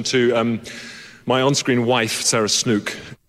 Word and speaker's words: Snook: We've been saying her name incorrectly. – Sarah? – The whole time. It's Snook. – Snook? – Yeah, I heard one person once saying this. I thought, oh, they've got that wrong. What an idiot. Apparently that's how you say it.